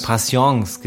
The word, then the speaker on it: Patience: Patience, genau.